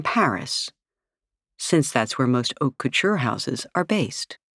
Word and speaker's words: haute couture: For women, it's known as haute couture, pronounced haute couture, a French term that translates to high sewing.